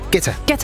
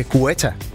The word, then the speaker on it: Guetta: Dengang jeg arbejdede på Kommercel Radio, der sendte han engang en fax om, at hans navn udtales Guetta. Og ikke Guetta, som alle os andre havde grund at kalde ham. – Hvis det er fransk, så er det Guetta.